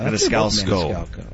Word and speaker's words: Maniscalco: Is it Maniscalco? Is that how you say it? The Sebastian? – That's the comedian's name, yes. – Oh, Maniscalco.